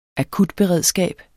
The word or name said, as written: akutberedskab